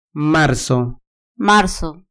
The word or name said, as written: marzo